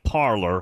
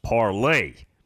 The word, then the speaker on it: Parler: Now, it's spelled P-A-R-L-E-R. So it looks like parlor, which is what I was calling it. And then Brenda said, it's pronounced Parlay. Like Rob in the 210, the Parlay King. That's what it's pronounced like, but it's spelled P-A-R-L-E-R.